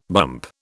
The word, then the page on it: bump: British English